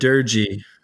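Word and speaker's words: Doerge: How do you pronounce that? – I heard Durgy. Like Durgy? Yeah. Okay.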